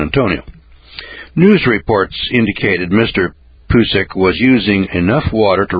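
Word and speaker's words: Pucek: Well, back in 1991, a fellow by the name of Ron Pusek, P-U-C-E-K, I'm going to pronounce it Pusek,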